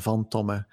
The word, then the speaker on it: Tomme: So Christoph is my first name, and von Tomme is my family name, and it's a Dutch name, double M-E.